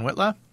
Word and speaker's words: Whitla: My name is Christopher John Witla. I go by John. Last name is spelled W-H-I-T-L-A.